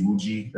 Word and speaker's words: Iwuji: Just look up my name, Jesse Wuji. That's J-E-S-S-E. Last name is I-W-U-J-I.